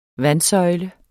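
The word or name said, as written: vandsøjle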